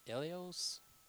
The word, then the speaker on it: eleos: Mercy in the Greek was eleos, I believe. E-L-E-O-S. Eleos.